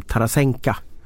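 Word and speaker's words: Tarasenka (Тарасенко): Jag vet att på ett VM för något år sedan när vi var på Duo Abris så valde ju Lasse Granqvist att uttala eh, den ryska storstjärnan Vladimir Tarasenko som jag säger. Som Tarasenka mm. uttalade han namnet.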